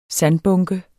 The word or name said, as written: sandbunke